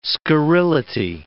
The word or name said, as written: scurrility